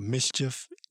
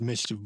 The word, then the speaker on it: MSCHF: If you need, it's called, I think they're called Mischief, but it's spelled M-S-C-H-F.